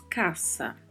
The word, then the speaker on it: casa: Caça, que é um substantivo que significa casa, e a particularidade de pronúncia da letra S, né, que está entre duas vogais, no entanto, em espanhol permanece com som de S. Então pronunciamos caça.